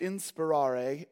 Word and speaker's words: inspirare: So inspiration, the term is derived from a Latin term, which is pronounced inspirare, and it literally means to breathe into.